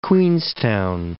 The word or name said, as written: Queenstown